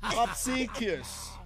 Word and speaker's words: obsequious: Obsequious. Oh, gentlemen, this is how you spell it. O-B-S-E-Q-U-I-O-U-S. Obsequious. – Obsequious.